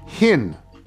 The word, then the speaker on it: Hinn: From Paige Hinn, because it says it rhymes with Schwinn, so Paige Hinn.